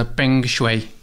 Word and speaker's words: Peng Shuai: Sei que nos últimos dias também acompanharam com preocupação o caso da tenista chinesa Peng Shuai certeza que é assim que se pronuncia.